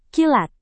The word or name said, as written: kilat